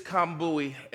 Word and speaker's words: Kambui: So, my name is Kambui, and uh, I'm going to put a slide up here that really helps you to see how that name is pronounced because it says K A M, and I know we're in America.